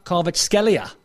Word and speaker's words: Kvaratskhelia: Kvaratskhelia looks a player, doesn't he? – Oh, there you go.